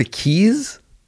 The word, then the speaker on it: Quay: The Keys? – Yeah, pronounced or like spelled Q-U-A-Y.